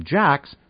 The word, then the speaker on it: Jacques: The man Maloney had met pronounced his name Jacks, but said it was spelled differently. Maloney said he thought it was J-A-C-Q-U-E-S,